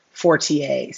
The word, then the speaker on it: Fortier: And on both of those, you can find me at Remy Fortier. So my last name is spelled F like Frank, O-R-T-I-E-R.